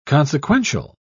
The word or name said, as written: consequential